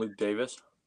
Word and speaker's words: Luke Davies: So Luke Davies is actually pronounced Luke Davis.